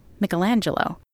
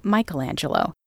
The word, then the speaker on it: Michelangelo: I am going to be slightly annoying and pronounce the artist's name in the Italianate way, Michelangelo, instead of the Americanized version of Michelangelo,